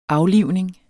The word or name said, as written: aflivning